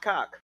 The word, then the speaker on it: Koch: That's not pronounced Koch. – Get out of here. How do you say it? – You want to know how it's pronounced? Cock.